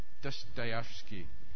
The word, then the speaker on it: Dostoevsky: Dostoevsky.